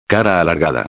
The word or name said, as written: alargada